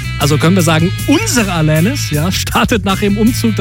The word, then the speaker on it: Alanis: Na gut, technisch gesehen spricht man sie Alanis aus.